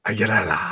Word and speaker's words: Aguilera: I can't say her name for me. – Aguilera. – Hey, thank you.